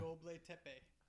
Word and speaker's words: Göbekli Tepe: Göbekli Tepe. It's spelled exactly as it sounds.